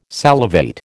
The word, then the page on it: salivate: American English